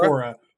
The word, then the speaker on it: Korra: And then once you finish that, you can hit up The Legend of Katara. I'm gonna say it wrong again. – The Legend of Korra. – Yeah, The Legend of Korra. I always want to say Katara, because that's her grandma. But The Legend of Korra, and that's another great show.